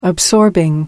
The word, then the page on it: absorbing: American English